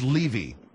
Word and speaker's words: Levy: Why do I get the feeling that you're thinking more about poor Gary Condit than you are about the Levies? I mean, the Levy. See, you got me saying it. The name is Levy.